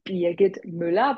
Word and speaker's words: Birgit Müller: And my name is correctly pronounced Birgit Müller, but all Americans or English friends and colleagues call me Bridget.